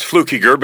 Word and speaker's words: Plukinger: There's no N in it. It's Plukinger, but that's fine.